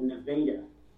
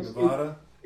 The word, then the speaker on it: Nevada: So it's spelled like Nevada? – Spelled like Nevada. Spelled, you know, but we called it Nevada.